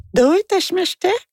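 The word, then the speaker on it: Doi tashimashite (どういたしまして): » Ben, j'ai dit « doit », elle me dit « oh non ». J'ai dit quoi? Non, je ne prononce pas correctement. Ouais. Elle dit non, il faut dire « Doi tashimashite ».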